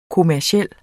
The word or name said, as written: kommerciel